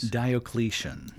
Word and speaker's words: Diocletian: Diocletian. – Diocletian, proclaimed emperor by armies of the East and West after the death of Carinus.